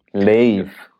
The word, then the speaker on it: Lave: L-A-V-E. – Lave. – Lave, weil, weil es Evel rückwärts ist.